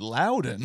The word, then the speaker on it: Loudon: So I did a lot of research on the train ride over, and what I found out about Lon Wainwright, did you know? – I happen to learn today that his name is pronounced Loudon.